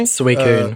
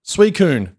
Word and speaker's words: Suicune: Uh, now, am I pronouncing this right? Suisune? Suisune? – Suicune. Uh, Suicune. Thank you.